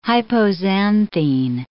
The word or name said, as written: hypoxanthine